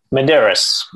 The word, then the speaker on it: Medeiros: Medeiros.